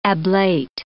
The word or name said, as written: ablate